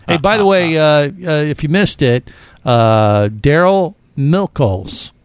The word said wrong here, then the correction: Miklos: Miklos. Miklos. – Milk-toast. – I said it wrong earlier. – Not Milktoast. – Mipos? – Miklos. Right? I'm saying Miklos.